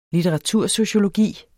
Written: litteratursociologi

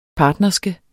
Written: partnerske

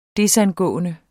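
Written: desangående

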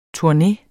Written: Turne

